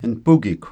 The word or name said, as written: npukikw